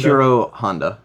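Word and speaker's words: Ishirō Honda: Ishiro Honda.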